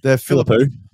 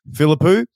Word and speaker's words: Philippou: Philippou. – Philippou.